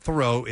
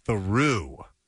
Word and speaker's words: Theroux: And in fact, it's not Theroux, it's Theroux. – Theroux. – As in, I walked through the door.